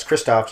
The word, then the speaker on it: Krzysztof: Yeah, it's Krzysztof, just like Kieslowski's first name, and then I guess we'll say Piszewicz.